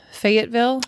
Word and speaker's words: Fayetteville: They lived in a city called Fayetteville, West Virginia. Mm-hmm. You know it? – I know Fayetteville, yeah. – Mm-hmm. Ah, oh, and I said it right. – You did. Good job.